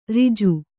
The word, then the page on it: ರಾಜಿ: rāji